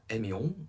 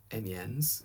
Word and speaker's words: Amiens: He did manage to secure the Treaty of Amiens, or Amiens, to give the proper English pronunciation, which sort of settled things for the moment.